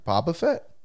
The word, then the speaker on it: Boba Fett: Boba Fett.